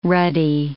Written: ruddy

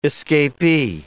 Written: escapee

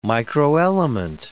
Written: microelement

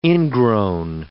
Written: ingrown

